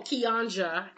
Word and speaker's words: Kianja: My earworm lately has been the cover that Bastille did with Craig David and Kiana. Oh, Kianja, actually. She confirmed that there's no silent J. It's Kianja.